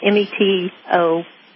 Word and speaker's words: metoprolol: One is clonidine, one is hydrochlorite, one is metrolol. I can't pronounce it. Metoprolol. – It's called metoprolol.